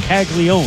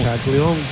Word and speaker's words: Caglianone: It's spelled C-A-G-L-I-A-N-O-N-E, like Caglianone, but it's just Caglione. – Caglione.